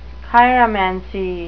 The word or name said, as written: chiromancy